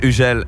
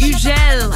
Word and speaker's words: Hugel: Hugel. Einfach französisch. Der Mann kommt aus Marseille und ich Hugel.